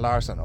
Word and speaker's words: Larsson: But the thing, the ace that AIK have up their sleeve today is no less than Jordan Larsson. Okay, in the Swedes will call him Jordan Larsson, we'll call him Jordan Larsson. This is the Irish and Sweden podcast, we go with that pronunciation.